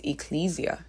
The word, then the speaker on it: ecclesia: So the church is a translation of the Greek word that is ecclesia.